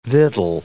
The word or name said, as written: victual